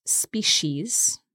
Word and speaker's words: species: And the word species is spelt S-P-E-C-I-E-S. We pronounce this species, so the C becomes a sh, but some people say species as well. – Okay, but you'll also hear two long E sounds there, so species.